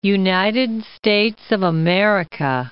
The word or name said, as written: United States of America